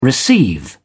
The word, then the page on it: receive: British English